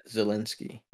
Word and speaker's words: Zelensky: Well, that's exactly what Zelensky... Wait, so what is that how you pronounce his name? – Yeah, Zelensky.